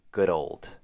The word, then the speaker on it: good old: For the first word, we have some blended words. Good old. For this blended combination, you want to blend the D ending from good into the word old, giving you dold. Good old. Good old.